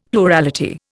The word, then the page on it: plurality: Indian